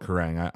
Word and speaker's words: Kerrang: Oh, yeah. I've always called it Kerrang.